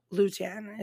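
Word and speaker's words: lutin: But this was the closest one that I could find to a Cajun pronunciation. So Lutan is what I got.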